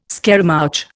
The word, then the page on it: scaramouch: Indian